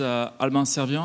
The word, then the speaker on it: Albin Serviant: Hi, my name is Albin Servian.